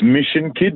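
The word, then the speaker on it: Myshon Kyd: It's Mission Kid, but it's spelt differently. So it's spelt, Mission is spelled MYSHON, and then the kid is KYD. Yeah, so it looks, when you look at it, it's My Sean Kid.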